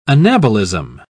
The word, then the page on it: anabolism: British English